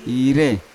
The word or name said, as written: ire